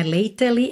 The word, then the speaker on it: lately: Mas olha, lately, você não pronuncia o E que vem depois da letra T, ok? Não é lately, é lately, lately.